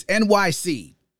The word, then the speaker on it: Enyce: how it's pronounced. It's NYC. Yes, NYC spelled E-N-Y-C-E.